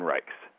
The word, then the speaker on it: Reichs: The easiest way to remember it is that it rhymes with bikes or likes. As in, I really likes Brendan Reichs.